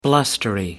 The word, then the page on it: blustery: American English